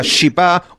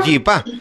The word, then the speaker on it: chipá: Según la grafía actual o grafía actual, en guaraní esta palabra se escribe chipá y se pronuncia parecido a chipá o yipá, según la academia.